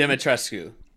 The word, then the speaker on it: Dimitrescu: Okay, also, she's Lady Dimitrescu, or I can never say her name. – Dimitrescu.